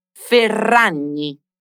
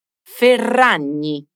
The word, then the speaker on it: Ferragni: Pronuncia bene questa doppia R e la gn Ferragni. – Ferragni.